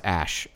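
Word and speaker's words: Æ: So it's just X, the letter X, and then the A-E is pronounced Ash.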